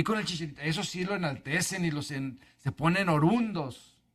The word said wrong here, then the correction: orondos: O sea, orondos.